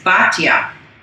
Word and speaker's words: Batya: But she, you know, in the pronunciation of that nickname in Hebrew is Batya.